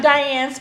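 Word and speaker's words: Dyahnne: And I'm Diane, spelled the black way. That's D-Y-A-H-N-N-E.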